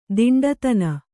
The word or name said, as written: ದಿಂಡುತನ